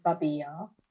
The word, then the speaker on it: Babi Yar: And the Russian pronunciation is Babi Yar. The Ukrainian pronunciation is Babyn Yar.